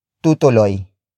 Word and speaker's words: tutuloy: And then finally we have tutuloy.